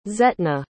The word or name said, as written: zetna